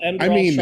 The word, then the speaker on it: Enderal: Enderal? – Enderal? – That sounds right.